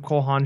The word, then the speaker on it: Cole Haan: No, Cole Haan, you're right.